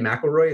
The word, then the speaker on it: McElroy: Ken McElroy, is that right? – Yeah, that sounds right.